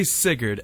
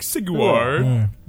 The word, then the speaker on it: Sigurd: You couldn't say Sigurd, and you just watched the episode, and they say Sigurd like 80 times, and you were like, Sigwar.